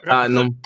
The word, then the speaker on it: Tottenham: The H is silent in the UK for those English speaking listeners. You know what I mean? It's hard. Like when they say it's like Tottenham, you can't say the H.